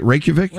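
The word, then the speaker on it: Reykjavík: Is it Reykjavik? – Reykjavik.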